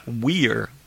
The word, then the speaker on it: Weier: Okay, so it's Anissa Weier or Via in German, and Morgan Geyser, same thing.